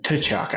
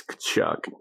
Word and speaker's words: Tkachuk: It's the T is silent. – She said Tkachuk, and I was like, oh. That's weird. Never heard it like that. – No, it's Kachuk.